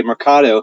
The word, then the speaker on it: Mercado: Yeah, so great question, and thanks for pronouncing it correctly, by the way, Mercado.